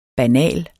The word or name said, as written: banal